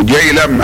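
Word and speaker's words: جهلم: وهذا النهر اسمه جلهم ولكنه ينطقونه جيلم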